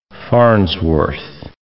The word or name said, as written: Farnsworth